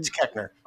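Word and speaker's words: Koechner: One thing I do want to talk about is – doesn't really have to do with the movie – is David Kochner and I don't think I'm saying that right. – Kechner. – Oh, Kechner. So David Kechner and Kristen Chenoweth both playing outside normal type, which I love.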